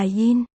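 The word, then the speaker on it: yin (银): Ngân trong tiếng Trung đọc là yin. Đồng âm với dâm trong dâm đãng. Phóng túng cũng đọc là yin.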